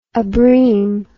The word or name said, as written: abrim